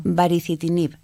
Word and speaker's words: baricitinib: El medicamento se llama baricitinib.